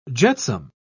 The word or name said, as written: jetsam